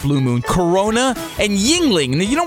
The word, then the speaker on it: Yuengling: Yingling, I say. Yingling. Spell it Yuengling.